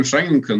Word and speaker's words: Шанин: Э, ну, мы обычно говорим Шанинка, но сам Теодор Шанин, который был нашим основателем, он э, произносил свою фамилию как Шанин.